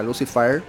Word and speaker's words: Lucyfire: se escribe Lucifire, un, un juego de palabras entre Lucifer y el fuego, ¿verdad? Lucifer, muy buena banda.